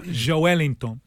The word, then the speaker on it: Joelinton: If you start with Joe, you won't. So yeah. Joelinton.